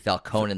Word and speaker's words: Falcone: And it's actually Falcone in this movie. – Falcone. It's just Falcone. – Different pronunciation.